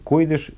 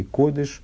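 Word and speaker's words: кодыш (קודש): Э, ну, вот, об, этом, об этом же разница между кодыш и, и, и, койдыш и кодыш. на сифарском произношении, на современном произношении, вернее, кодыш и кадош.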